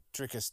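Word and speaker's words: Dricus: Driscus Duplessis.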